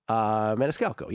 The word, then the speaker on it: Maniscalco: But it was in recent years, starring the great Sebastian Maniscalco. Is that how you pronounce it? Maniscalco, I believe. Maniscalco, yes.